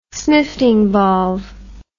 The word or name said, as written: snifting valve